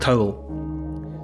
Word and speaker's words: teabhal: A towel is a kind of an anglicisation of an Irish Gaelic word that's spelt T-E-A-B-H-A-L, but it's pronounced more or less like towel.